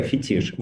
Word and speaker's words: фетиш: Или фетиш, тут уж как кто хочет, ставит ударение, но по словарям пока фетиш.